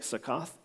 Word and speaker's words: Sukkot: In the Jewish language, I'm going to probably mispronounce this, but it's Sukkot, I think, Sukkoth.